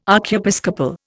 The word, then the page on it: archiepiscopal: Indian